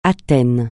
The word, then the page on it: Athènes: francais